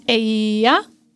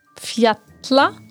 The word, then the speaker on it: Eyjafjallajökull: You just have to put the word into three parts. Eyja. – Yeah, island. – Fjalla. – Mountains. – Jökull. – Glaciers. – So the double L in this word, you just pronounce as if you would read a word with a DL. And then you say Eyjafjallajökull.